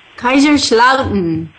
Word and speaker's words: Kaiserslautern: Kaiserslautern. – Yeah. – Did I say it right? – Yes, you said it right.